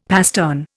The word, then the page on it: pastern: Indian